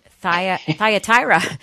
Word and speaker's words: Thyatira: Thyatira.